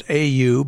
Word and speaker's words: Au: Her last name is spelled A-U, but it's pronounced Au.